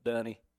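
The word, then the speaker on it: Donne: It's spelled Don. I've heard it pronounced that way, but I think, if I remember correctly, it was Dunne. You pronounce that Dunne.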